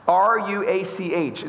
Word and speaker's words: ruach: R U A C H. It's pronounced ruach. It's a Hebrew word and it's translated as spirit or breath.